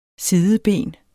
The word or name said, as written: sideben